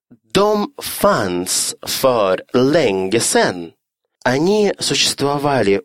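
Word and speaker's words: de: Грамматически правильная состоит всего из двух букв «d» и «э», а читается также «дом».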